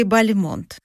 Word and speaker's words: Бальмонт: Бальмонт или Бальмонт? – Конечно. Всегда делаю ударение правильно. Правильное ударение Бальмонт. Ну, хотя и Бальмонт, и Бальмонт, возможно. Почему? Да все очень просто. Когда Бальмонт уехал во Францию, решил называть себя на французский манер.